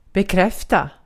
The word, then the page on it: acknowledge: American English; RP; /əkˈnɒlɪdʒ/; /ækˈnɑl.ɪdʒ/